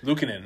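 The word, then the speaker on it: Luukkonen: Did you see, kind of speaking of little temper tantrums, it wasn't exactly a temper tantrum, but how do you say the Finnish goalie's name? Like Lakanen? – Uka Pekka. Lukanen. – Lukanen, that's it.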